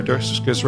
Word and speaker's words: Durskis: Sarah Durskis, Rhymes with Circus,